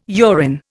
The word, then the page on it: urine: Indian